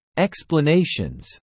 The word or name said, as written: explanations